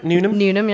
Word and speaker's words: Newnham: Newnham, yeah.